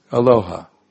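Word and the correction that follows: Ahalya: What's that? Ahaya, yeah, I pronounced it wrong, I thought.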